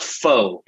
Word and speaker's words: faux: It's faux. It's pronounced faux, Blackie.